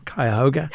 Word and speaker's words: Cuyahoga: Cuyahoga.